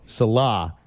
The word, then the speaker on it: Salah: Salah. – Salah. Mm-hmm. I think it's spelled Saleh, but pronounced S-A-L-A-H.